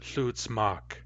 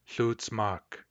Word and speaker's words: hlutsmak: So we actually pronounce it hlutsmak. Hlutsmak. My wife, hlutsmak kas. Hlutsmak kas.